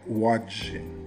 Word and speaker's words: Łódź: Tem uma cidadezinha que eu nunca ouvi falar, né, que se escreve L-O-D-Z e se pronuncia Łódź. Fica na Polônia, né? Então quando você vê assim, parece que você pronuncia Lodz, né? mas na verdade a pronúncia é Łódź.